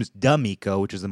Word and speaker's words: D'Amico: D apostrophe Amico, pronounced D'Amico. You can tell somebody doesn't know what they're talking about because they pronounce it D'Amico. It's D'Amico, which is a weird name too because that means of friend, which is almost like a weird way of calling someone an acquaintance.